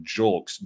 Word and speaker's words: Julks: I'm going in with the hard J, Julks, J-U-L-K-S, Houston Astro.